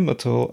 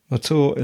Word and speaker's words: Mathur: The show that you just tolerated is made by me, Roy Mathur, a writer. Mathur is spelt M A T H U R.